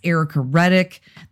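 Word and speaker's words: Ericka Redic: For me, it's Erica Reddick. That's E-R-I-C-K-A-R-E-D-I-C, because I know y'all spell my name wrong.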